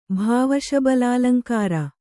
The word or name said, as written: ಭಾವಶಬಲಾಲಂಕಾರ